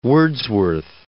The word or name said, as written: Wordsworth